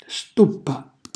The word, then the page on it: stuppa: Calabrese